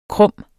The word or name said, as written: krumme